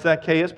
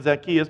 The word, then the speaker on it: Zacchaeus: Once you go to Bible college, you realize it's Zacchaeus, but you can't get over saying Zacchaeus because it rhymes in the little song.